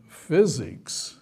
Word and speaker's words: FSIX: I call it Physics Operating System, and I spell it FSIX, stands for Functionally Structured Linux, or Unix. And Physics is the way you pronounce it.